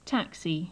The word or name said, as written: taxi